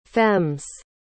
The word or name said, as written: femmes